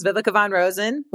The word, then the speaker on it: Viveka von Rosen: So my name is Viveka von Rosen, which you got absolutely correct, because there's a new feature on LinkedIn called voice pronunciation.